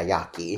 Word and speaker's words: Yaqui: Yeah, Yaqui is how you pronounce that.